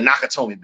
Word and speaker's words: Nakatomi: The Yakatomi Building. The Nakatomi Building.